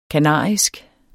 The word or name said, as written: kanarisk